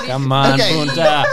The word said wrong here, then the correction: puta: Did you just say it wrong? Spell it for me. What's it supposed to be? – P-U-T-A. – No, spell it. Don't fucking talk it slowly. – Spell it in Spanish. – P-U-T-A. Okay, so it's like puta. – There you go.